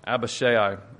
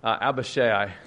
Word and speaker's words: Abishai: But I'm actually thinking of Joab's brother, Abishai, as I pronounce it. Abishai, a little bit less known, probably the older brother of Joab.